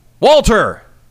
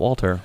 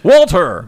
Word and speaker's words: Walter: How do you say his name? – Walter! Not Walter. Walter!